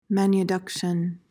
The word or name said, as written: manuduction